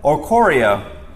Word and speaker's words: Oricorio: I think this is Oricoro? Oricoro? Oricorio. That sounds fancier.